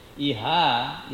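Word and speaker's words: īhā: Iha is desire. – Okay. – Yeah. Iha. – So that's the word that's used there, Iha. – Yeah, Iha. It's with long A, Iha means desire.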